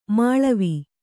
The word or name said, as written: ಮೇಳಾವ